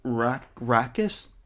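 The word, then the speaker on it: Raxus: It's pronounced Rakkis. – Rakkis. I think so.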